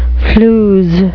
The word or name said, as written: flews